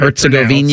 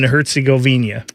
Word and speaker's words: Herzegovina: That's how I've always heard Herzegovina. – It pronounced. That's how, at least in any Olympics, I've always heard them say Bosnia and Herzegovina.